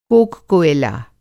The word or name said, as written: कोक-कोयला